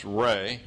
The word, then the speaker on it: Ra: You'll see sometimes his name spelled R-E, pronounced Ray, or R-A, pronounced Ra.